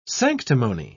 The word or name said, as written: sanctimony